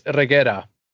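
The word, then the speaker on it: Reguera: I've got much more ideas, but we have Dave Bautista in here, Ella Purnell, Omari Hardwick, Ana de la Rugra. Damn, I don't think I said that right. Regrugra? Damn it. I don't know. Theo Rossi. – Yeah, I think it's Regera.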